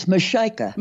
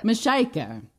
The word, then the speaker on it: MaShieka: I hope I'm pronouncing that right, Rabia. She is there listening to this. Am I pronouncing that right? – It's MaShieka. – MaShieka.